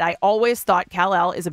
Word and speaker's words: Kal-El: Nicolas Cage says that his son, Kal-El, is that how you say it? Kal-El? – Kal-El, yes, from Superman.